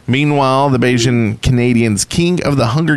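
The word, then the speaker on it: Bajan: Is that how you say it? – It is. – It's Bayesian? Okay.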